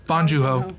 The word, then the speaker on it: Bong Joon-ho: Because it just got a lot of hype in the last couple months, and obviously, like, Bong Joon-ho's, like... – Bong Joon-ho. – Yeah, definitely said that wrong.